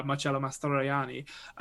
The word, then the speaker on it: Mastroianni: And it's essentially this director who's made one successful film and he's struggling to make his next one, played by Marcello Mastroianni, who is brilliant in it.